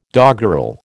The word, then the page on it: doggerel: American English